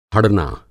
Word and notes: हड़ना: Hindi

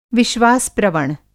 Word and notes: विश्वास-प्रवण: Hindi

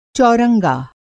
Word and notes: चौरंगा: Hindi

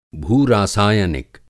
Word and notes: भूरासायनिक: Hindi

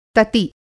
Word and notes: तति: Hindi

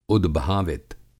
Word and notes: उदभावित: Hindi

